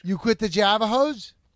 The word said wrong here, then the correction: Jehovah: Javajo? No. Wrong. You pronounce it wrong. It's Jehovah. Jehovah's Witness.